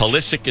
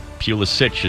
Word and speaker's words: Pulisic: Think Pulisic is the American, Pulisic is the Croatian.